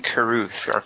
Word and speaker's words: Carruth: It was written, produced, and directed by Shane Carruthers. Is that his name? How do you say his name? – It's not Carruthers. I think it's Shane Carruth.